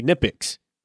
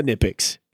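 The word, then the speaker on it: Knipex: I me me like you and pretty much almost all of uh, mechanics in here in, in the US, we say Nipix, but the actual company that makes it calls it Nipix. Like they pronounce the K in in Nipix.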